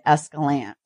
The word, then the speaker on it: Escalante: So that's how I originally said it, was Escalante, but town, you can tell if you're local if you say it Escalante. That's how it's supposed to be said, Escalante.